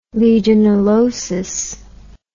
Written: legionellosis

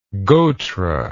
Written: gotra

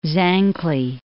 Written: Zancle

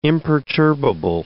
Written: imperturbable

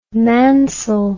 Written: Mansell

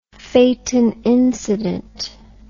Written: phaeton incident

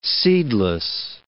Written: seedless

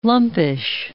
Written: lumpish

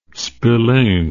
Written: spillane

